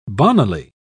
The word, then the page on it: bonnily: British English